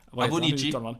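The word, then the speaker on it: Awoniyi: Han är ju inte en, eh, som jag fortfarande inte kan uttala, en Taivo Avonji Avoniji. – Avoniji, tack.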